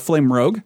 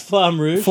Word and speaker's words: Flamme Rouge: We played a game of Flame Rogue, which... – also known as Flamme Rouge. – Yeah. – Pronounce it properly.